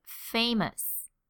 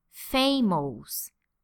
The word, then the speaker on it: famous: Here in Brazil, it is very common to hear people pronounce words like famous and anxious like this: famous and anxious. It is a very common mistake. So I'm going to tell you exactly how to pronounce these words. Here we go. Famous and anxious. Not famous and anxious. These sound like Portuguese. Famous.